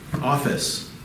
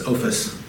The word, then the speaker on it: ophis: Serpent, another Greek word, office. I know that sounds like an office in business, but it's not. It's ophis. It's probably a better way to pronounce that.